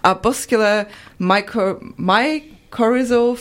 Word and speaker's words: arbuscular mycorrhizal: arbuscular mycorrhizal fungal network.